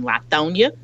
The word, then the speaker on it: Lithonia: They lived out in Lithonia, which is how you say it in Atlanta, in Lithonia, Georgia, and where lots of middle class, working class African Americans live.